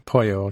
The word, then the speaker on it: Poyo: Poyo, okay, right, yeah. – Yeah, he's yes, like chicken, but we anglicized it to be spelled like yo-yo, but with a P, yeah.